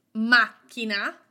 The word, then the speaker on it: macchina: Some words have their stress on an unexpected vowel. For example, macchina means car. We have three syllables, mac, chi, na. The accent is not on the syllable before the last one. It is on the very first syllable, mac, mac, macchina.